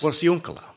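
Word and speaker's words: Porciúncula: Anyway, the funny thing about this map is when you look at it, it refers to the river as the Rio Porcincula, or Porcincula, however it's pronounced. – Porcincula. – That's much better.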